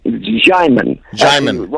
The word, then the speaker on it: Jaiman: Jaiman. Rhymes with diamond.